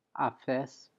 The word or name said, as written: afes